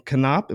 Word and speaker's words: Konop: But yeah, we have the director and creator of Spider-Man Lotus, Gavin Konop.